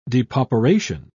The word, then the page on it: depauperation: British English